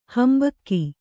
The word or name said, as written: ಹಿಂಭಾಗ